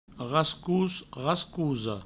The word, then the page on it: rascos, rascosa: provençal; rascous, rascouso